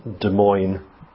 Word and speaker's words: Des Moines: When you look at it, you think, that's not how you pronounce Des Moines, but it's pronounced Des Moines, Iowa.